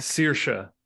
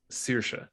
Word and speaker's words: Saoirse: Saoirse. Her name is Saoirse. – Oh. Is that how you pronounce her name? – Saoirse Ronan, yeah. – Then why is it written with the wrong letters? – Because it's Irish.